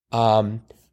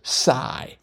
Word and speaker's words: psi: One of them is psi, and the other is just psi.